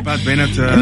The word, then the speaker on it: Pat Benatar: Pat Benatar.